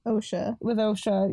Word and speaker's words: Osha: Osha. – Yeah. – When is Asha in the woods with Bran? – It's spelt with an O and I still say the sound, you know? – Yeah.